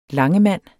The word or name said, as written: LANGEMANDEN